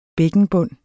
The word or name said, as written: bækkenbund